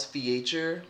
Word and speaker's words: Feacher: The people can find me on Instagram at Miles Feacher. My last name is spelled F-E-A-C-H-E-R.